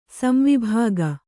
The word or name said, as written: ಸಂವಿಭಾಗ